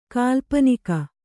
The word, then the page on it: ಕಾಲ್ಪನಿಕ: kālpanika